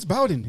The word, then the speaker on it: Bowden: but you can't say Bobby Bowden? – It's Bowden. – No, it's Bowden. It's always been Bowden.